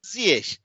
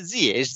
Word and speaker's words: Ziyech: Arapça Ş harfiyle bitiyor ki İngilizce'de büyük ihtimal sen hani tereciye tere satmıyorum ama CH şey diye okunuyor. Ee, herhalde İngilizce çevirisi de herhalde oradan e, yani daha doğrusu. C CH, CH falan diye okuyorlar onu ama Arapçadan hani şey yapmış. Evet Oradan Ziyech şeklinde spiker Raşov yıkmaya çalıştım maç boyunca söyleyeceğim söyleyeceğim biraz gizem katmaya falan çalıştı işin içine.